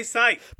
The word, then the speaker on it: Scythe: How to pronounce Scythe. – Say Scythe. – But it looks like Seth.